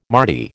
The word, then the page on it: mardy: American English